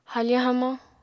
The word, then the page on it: hafañ hamañ: Apatani